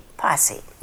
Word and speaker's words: Possie: Your name is Posse, and It's spelled P-O-S-S-I-E, Posse.